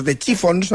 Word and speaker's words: The Chiffons: Años antes había triunfado otra creación, He's So Fine, compuesta por Ronald Mac e interpretada por The Chiffons, no sé si lo he dicho bien. – Lo has dicho estupendamente.